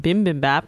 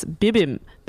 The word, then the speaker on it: bibimbap: Well, something that I made fresh the other day was a bibimbap, which I've been saying wrong this whole time. I've been saying bibimbap. It's bibimbap. – Bibimbap. – Bibim. – How do I spell that, please? B-I-B-I.